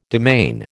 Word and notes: demesne: American English

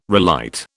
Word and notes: relight: British English

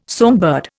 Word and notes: songbird: Indian

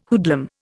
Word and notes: hoodlum: Indian

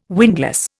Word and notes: windlass: Indian

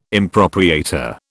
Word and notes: impropriator: British English

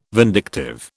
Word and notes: vindictive: British English

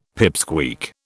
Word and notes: pipsqueak: British English

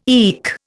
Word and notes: eke: Indian

